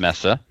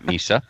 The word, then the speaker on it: Mesa: That's pronounced Mesa, not Misa.